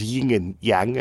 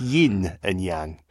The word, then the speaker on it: yin and yang: It's got, and then you realise that actually I've been saying that wrong. My whole life, I just thought it was yin and yang, and it's yin and yang.